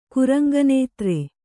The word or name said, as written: ಕುರಂಗನೇತ್ರೆ